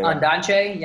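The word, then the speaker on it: Ondaatje: It was directed and written by Anthony Minghella, based on the English Patient novel written by Michael Undach, I think is how you pronounce his last name. He's a... – Undache? Yeah, he's Undache, like from Sri Lanka.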